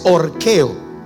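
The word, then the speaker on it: orqueo (ὀρχέω): El término es orqueo, se escribe orceo, O-R-C-E o orqueo.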